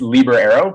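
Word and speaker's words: Looby: Yeah, it's Liber Iro, but I was very much of the same pronunciation when I first got into the field as well.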